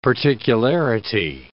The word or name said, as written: particularity